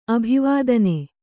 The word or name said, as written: ಅಭಿವಾದನ